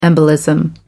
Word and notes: embolism: American English

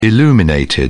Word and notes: illuminated: British English

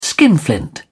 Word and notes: skinflint: British English

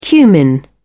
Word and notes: cumin: British English

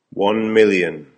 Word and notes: one million: American English

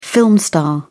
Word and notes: film star: British English

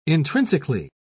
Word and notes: intrinsically: British English

